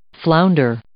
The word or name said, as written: flounder